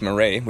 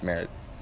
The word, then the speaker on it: Meret: I pronounced Meret, which seemed obvious to me because it was Italian. – Yeah. – And, you know, it's spelled M-E-R-E-T, and then our friend Michael, the old lady who's been on a couple episodes and will be joining us for the second one tonight, said he calls me out that week. He's like, it's pronounced Merit, and so I looked it up, and sure enough it is.